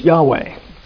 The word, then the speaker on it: Yahweh: A common name for God in the Old Testament is how we pronounce this, Yahweh.